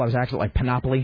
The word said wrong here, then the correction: panoply: Panoply, yeah. – And so somebody, this woman, Kathy, was like, it's panoply! Stop it!